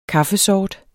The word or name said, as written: kaffesort